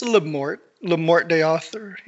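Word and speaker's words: Le Morte d'Arthur: How do you actually pronounce Lamorte? Is it Lamorte or something like that? I've only ever read it. I've never heard it pronounced. – All right. If it's Lamorte de Arthur.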